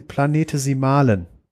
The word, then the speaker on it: Planetesimale: Das heißt, je näher am Protostern ist, desto weniger Elemente können auskondensieren und zu sogenannten Planetesi Planetesimalen. Spricht man das so aus? – Ja, Planetesimale.